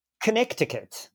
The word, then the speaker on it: Connecticut: Connecticut. – I know the spelling of it is bonkers, but you don't pronounce the middle C.